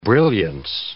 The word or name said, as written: brilliance